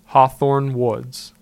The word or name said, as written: Hawthorn Woods